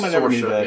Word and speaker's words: Saoirse: Is that how you pronounce her name? – Saoirse, yeah.